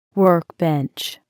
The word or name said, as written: workbench